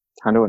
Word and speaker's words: Hondo: I keep saying Honda. – Hondo Inaka? – Yeah.